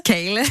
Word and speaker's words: kale: Alors, tout à l'heure, je parlais du chou kale. – Kale. – Kale, ça y est, je le dis bien, c'est bon.